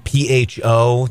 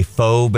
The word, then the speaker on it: pho: It's kind of like how when people see P H O, they say faux, but it's actually pronounced pho.